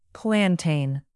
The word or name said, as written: plantain